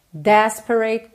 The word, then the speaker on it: desperate: Desperate caress. Atenção aqui às sílabas tônicas. Desperate é a primeira, caress é a segunda, ok?